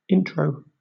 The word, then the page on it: intro: British English